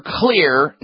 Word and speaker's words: Xlear: Just remember, Clear nasal sprays. Find the one that's best for you. And Clear is spelled X-L-E-A-R. That stands for the X in it. Stands for the xylitol.